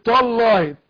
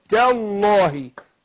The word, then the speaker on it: تالله: الله يديك الصحة والعافية يا رب بقول ايه ما اقولش تالله طاء نطقت التاء طاء اقول تاء تاء تالله تالله